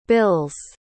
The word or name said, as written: bills